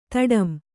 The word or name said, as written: ತಡಂ